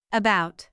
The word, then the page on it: about: əˈbaʊt